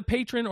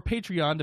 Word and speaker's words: Patreon: And if you have not looked at the Patron or Patreon, depending on the pronunciation, please go take a look at that.